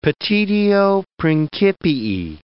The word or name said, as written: petitio principii